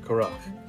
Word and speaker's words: Korach: And Korach, actually, is the way you pronounce it, Korach.